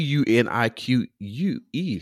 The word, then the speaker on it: Eunique: And it's and it's and I don't know it's it's E U N I Q U E. So that's that's unique, right? The E is silent.